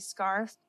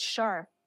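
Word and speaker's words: Scharf: Even my own father couldn't say it right. He kept calling me Scarf. – Okay. Well, then I feel less bad. – Oh, Daddy, it's Sharp. Okay.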